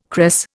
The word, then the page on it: cress: Indian